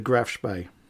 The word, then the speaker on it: Spee: It's spelt Spee, S-P-E-E. But it's pronounced the Graf Spee.